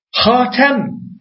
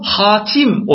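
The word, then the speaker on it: Hatem (خاتم): Asım kıraatında bu Hatem olarak okunuyor. Hatem. Diğer birçok kıraatta da bu Hatim olarak okunmuş. Yani Hatem Hatim.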